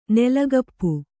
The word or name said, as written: ನೆಲಗಪ್ಪು